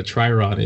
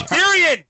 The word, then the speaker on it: Tyrion: I'm sorry that I don't know what a Tyrion is, but it's Tyrion.